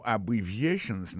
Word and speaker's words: abbreviations: Abbreviations, accent tonique là, tombé sous troisième syllabe là. Nan mot ça, gen quatre syllabes. C'est A, bon syllabe, bri, c'est deuxième syllabe là, vie, c'est troisième syllabe là, chan, c'est quatrième syllabe là.